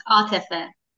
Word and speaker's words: Atefeh: But I mean, Atef, that's how I pronounce your name? – Okay. You can call me Ati, but the correct pronunciation is Atefeh.